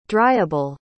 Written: dryable

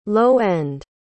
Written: low-end

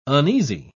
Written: uneasy

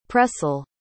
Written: Pressel

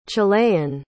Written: chilean

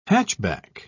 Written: hatchback

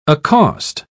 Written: accost

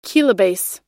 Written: kilobase

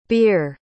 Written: bere

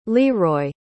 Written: leeroy

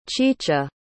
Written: chicha

